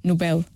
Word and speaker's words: Nobel: Nós quando pronunciamos, por exemplo, papel e anel, nós pomos a sílaba tónica na segunda sílaba. Nobel. Nobel, Noel. E Nobel é exatamente a mesma coisa. Além disso... E a principal razão é que o nome do fundador deste prémio chama-se Nobel.